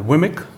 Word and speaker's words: WMIC: Um, WMI obviously is uh, very important, and a few years uh, Jeffrey Snover told us that um, WMI and Wimic, Mm -hmm. Do you pronounce it like that? Yes.